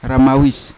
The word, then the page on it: ramawis: Yawa